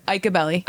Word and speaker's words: Iacobelli: Iacobelli.